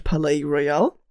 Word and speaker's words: Palais Royal: Insbesondere der kanadisch-US-amerikanischen äh, Band Palais Royal.